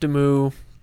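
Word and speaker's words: Demoux: One of the big reasons for that chapter is the Cosmere connection to Galadon and He writes it French. It's pronounced Demu.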